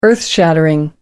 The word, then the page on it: earthshaking: American English